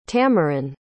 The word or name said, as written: tameron